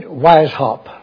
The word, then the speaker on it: Weishaupt: That name is spelled Weishaupt. We pronounce it Weishaupt.